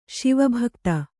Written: ಶಿವಭಕ್ತ

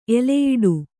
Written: ಎಲೆಯಿಡು

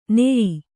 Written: ನಯ

